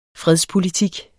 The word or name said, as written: fredspolitik